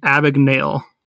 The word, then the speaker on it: Abagnale: It's pronounced Frank Abagnale.